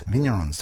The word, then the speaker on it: Vignerons: Vignerons, do I always say that right? Yeah, cool.